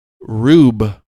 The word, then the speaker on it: Rube: Is it Rube Goldberg or Rube Goldberg? How do you say that? – Rube, I believe. – Rube Goldberg. – R-U-B-E. – R-U-B-E.